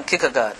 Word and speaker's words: Kierkegaard: Kierkegaard. Is that? Kierkegaard. Soren Kierkegaard.